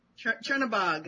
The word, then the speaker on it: Chernobog: Chernobog? – Chernobog.